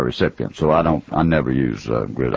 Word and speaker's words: Grizzard: There are people who spell G-R-I-Z-Z-A-R-D, and there are people who do pronounce it Grizzard. My family has never said Grizzard. I'm accused sometime of, now that I've written a book and so forth, they're changing it to Grizzard to Grizzard, but it is Grizzard. That's the way my daddy said it.